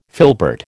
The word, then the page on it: filbert: American English